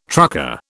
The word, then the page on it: trucker: British English